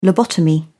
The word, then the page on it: lobotomy: British English